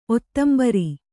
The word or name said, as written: ಔದುಂಬರ